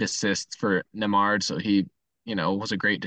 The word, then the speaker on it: Nembhard: Am I saying that right? Nembhard? – Nemard.